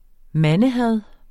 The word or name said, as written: mandehad